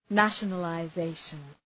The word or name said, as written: nationalization